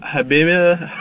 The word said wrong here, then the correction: Amor prohibido: Amor prohibido.